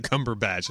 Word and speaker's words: Cumberbatch: Danielle, I think you may have just mispronounced. You said Cumberpatch instead of Cumberbatch.